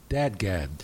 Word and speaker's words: DADGAD: Wait a minute, dadgad? – Dadgad is a tuning, a guitar tuning. And it's a sounds like how it's spelled from bottom to top, DADGAD, and it's an open sort of modal tuning.